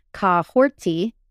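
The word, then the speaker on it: Kahhori: So her pronunciation of her name, while it's spelled Kahori, it's actually Kahorti.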